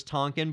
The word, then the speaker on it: Tankian: I was listening to him say it and he kind of made it sound like it was Tonkin, but what do you think? I've always said it Serj Tankian because it's spelled T A N K I A N.